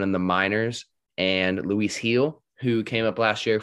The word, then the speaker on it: Gil: How do you pronounce his name? – Heel. Yeah, Heel. Luis Heel. – Stupid. – I hate him now for that reason. – I thought it was Jill for the longest time.